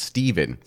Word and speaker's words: Stefan: 1331, King Stefan Uros III, after months of anarchy, surrenders to his son and rival, Stefan Dusan, who succeeds. – No, you're not pronouncing it right. It's Stephen.